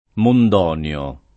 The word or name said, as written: Mondonio